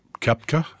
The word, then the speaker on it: Koepka: Can you imagine if Koepka, is how you say? – Yeah, or Mickelson.